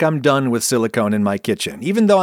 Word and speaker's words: silicone: The difference is the silent E at the end of silicone that tells you to make a long O sound instead of a short one.